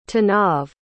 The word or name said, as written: tanav